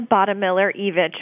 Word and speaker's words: Bottemiller Evich: It's Helena Buttmiller-Evich, but you are certainly not the first person to get it wrong, so no worries.